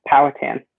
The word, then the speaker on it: Powhatan: He attends high school at, in a public high school setting in rural Virginia, enrolled in Powhatan's. Did I say that right? – It's Powhatan. Oh, no, I didn't. Mm-hmm. – It's in fine. Powhatan, Everybody messes it up.